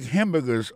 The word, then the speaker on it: hamburger: N'a pas remarqué que dans le mot hamburger, ça a comme tu as dit, il y a trois syllabes là-dedans. Quand tu appuies et puis ça, c'est sur le premier syllabe là.